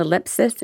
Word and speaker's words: Ellipsis: Ellipsis International. Did I pronounce that right, Natalie? – Yes, you did. It's a little tricky.